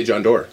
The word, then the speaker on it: Doerr: How do you say it? It's just Doerr, right? – Yeah, Doerr? – Sure. Yeah, John. – No, what do you say? – I would say John Doerr. – Yeah, that E is silent.